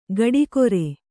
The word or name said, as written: ಗಡಿಕೊರೆ